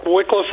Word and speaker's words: Wycliffe: Wycliffe, Ohio. Am I saying that right? – That's correct. Wycliffe, Ohio.